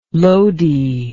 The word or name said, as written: loadie